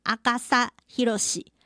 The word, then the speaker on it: 阿笠博士: 阿笠博士他的名字呢，日文发音叫做阿嘎萨希罗西。